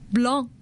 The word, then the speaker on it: blanc: Blanc. You don't have to pronounce the c at the end. – No except if you use it uh, with the feminine words, you will say blanche。